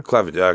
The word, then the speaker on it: Clevinger: So what is this pitcher, Clevinger? – Mike Clevinger? – Clevinger, okay. I wanted to make sure I was pronouncing it right.